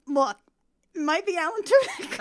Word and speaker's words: Alan Tudyk: Alan Tudyk? Is that how you spell that? – Yeah, Alan Tudyk, yeah.